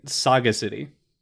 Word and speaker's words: Sagacity: Errant Sagacy. Oh, that said that wrong. Errant Sagacity.